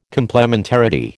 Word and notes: complementarity: American English

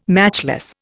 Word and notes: matchless: Indian

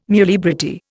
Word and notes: muliebrity: Indian

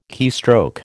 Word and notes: keystroke: American English

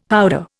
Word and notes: powder: Indian

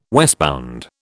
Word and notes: westbound: British English

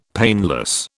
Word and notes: painless: British English

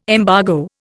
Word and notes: embargo: Indian

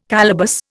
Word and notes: calaboose: Indian

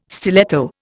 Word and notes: stiletto: Indian

